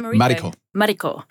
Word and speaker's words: Mariko: Mariko.